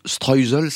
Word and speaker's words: Streusel: Et qu'est-ce que vous pensez alors de ce streusel, c'est la bonne prononciation? – Streusel.